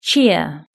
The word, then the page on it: cheer: British English